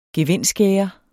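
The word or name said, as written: gevindskærer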